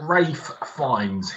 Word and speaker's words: Rafe Fiennes: Ask Rafe Fiennes himself, or Ralph. – I'm not wrong, right? I'm not pronouncing this guy's name as Rafe because I'm an asshole. That's what he goes by, right? – Yeah, that's the name he goes by.